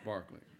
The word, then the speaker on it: Berkeley: And as Bacon's armed rebellion grew, it turned against Governor Barclay. Y'all can practice that. That's spelled Berkeley, pronounced Barclay.